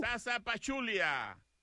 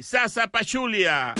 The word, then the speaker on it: Zaza Pachulia: Z-A-Z-A? Here's the tough part. P-A. P-A-C-H. U-L. I-A. – Sasa Pachulia. Z-A-Z-A-P-A-C-H-U-L-I-A. Sasa Pachulia. – Yes, although he did technically spell it P-A-P-A, Papa Chulia, but that's okay, which is totally different.